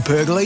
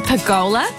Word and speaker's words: pergola: Hey Kate, have you seen Luke's new Sunview pergola yet? – Don't you mean pergola? – Pergola, pergola, it doesn't really matter how you say it.